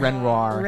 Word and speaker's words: Renoir: Renoir. That's how you say it.